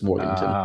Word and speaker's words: Morganton: Yep, there's no W. People get it confused with Morgantown, West Virginia a lot, but it's Morganton.